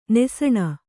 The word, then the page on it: ನೆಸಣ: kannada